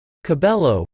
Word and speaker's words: Cabello: Cabello, pronounced C-A-B-E-Y-O, is set to perform at the Grammys on Sunday, January 26.